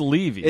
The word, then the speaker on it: Levy: Also, Dan Levy, whose name I always get wrong. – It is Levy. – It is Levy. Here's the problem. Okay, it's spelled L-E-V-Y. – Okay. – Okay, Jane Levy, who is on our show, is spelled L-E-A-V-Y. So, to me, there's no way that L-E-V-Y and L-E-A-V-Y are the same. So, I think, oh, Jane is Levy, so Dan must be Levy.